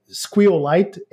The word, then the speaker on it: SQLite: I don't use it in all podcasts or like in any appearances because people are more familiar with either SQL or SQL. But I actually find Squeal Lite and Leave Squeal, which is our fork, just the best pronunciation.